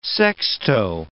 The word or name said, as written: sexto